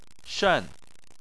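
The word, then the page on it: shen: tone 4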